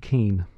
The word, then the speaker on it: Kuehn: Andrew Keane. He sounds Irish, but Keane is spelled K-U-E-H-N.